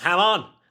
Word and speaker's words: Hamon: It's Hamon. Say it right.